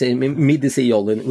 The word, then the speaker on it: Minisjolin: The pronunciation is quite good, actually. It's Minisjolin, which is actually from the part of Norway,